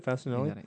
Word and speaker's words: Facinelli: Peter Facinelli. I don't know if that's how you say it. Facinelli. – Let me see it. – It's his. He plays Mike Dexter. – Yeah, Peter Facinelli. – Oh, Facinelli. Oh, is it Facinelli? – Yeah, you had it right.